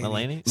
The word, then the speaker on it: Mulaney: Like, Mulaney? – Stop saying it wrong. It's Mulaney.